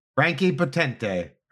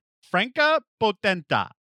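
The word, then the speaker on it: Franka Potente: Like I took Italian. I say everything like it's Italian. But Kelly went to Google to pronounce this name, and I must have heard it 50 times last night, and now I can't remember. Franca Potenta. – That's better.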